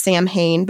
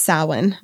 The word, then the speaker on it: Samhain: It's not pronounced Samhain, but that's what it looks like. – It's not? – No, it's Samhain.